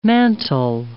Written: mantle

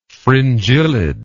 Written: fringillid

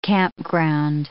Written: campground

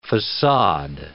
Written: facade

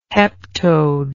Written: heptode

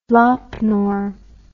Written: Lop Nor

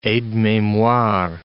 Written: aide-memoire

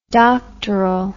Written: doctorally

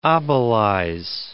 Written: obelize

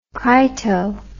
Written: crito

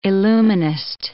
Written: illuminist

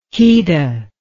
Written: keta

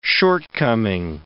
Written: shortcoming

